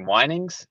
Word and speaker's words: Wynings: Wynings? – Wynings Roberts. Yes, you got it. – Wynings Roberts.